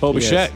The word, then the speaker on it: Bichette: Tell me if I'm saying it right, Bo Bichette? – Bo Bichette, yes.